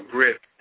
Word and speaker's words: GRIP: And with the Get Real Youth Project, or GRIP as it's pronounced,